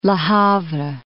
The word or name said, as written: Le Havre